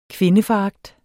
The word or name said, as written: kvindeforagt